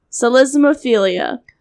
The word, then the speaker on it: psellismophilia: Celismophilia. I think. – Is it P-S? – Yeah, P-S-E-L-L-I-S-M-O-P-H-I-L-I-A. – Yeah, I would say that the P is silent. – Celismophilia.